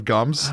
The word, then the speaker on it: Gumbs: It's Baxter, B-E-L-L. – Baxter Ball. – How do you mispronounce Bell? – Baxter Ball. Gims instead of Gums. – Oh, come on. It's just... – It's five letters. G-U-M-B-S.